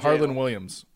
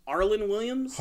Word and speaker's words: Harlan Williams: Harlan Williams. – Arlen Williams? – Harlan.